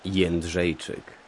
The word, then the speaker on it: Jędrzejczyk: Here is the proper pronunciation of our fighter's name. It's Joanna Jędrzejczyk. – Jędrzejczyk o qualcosa del genere, credo.